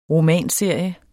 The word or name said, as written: romanserie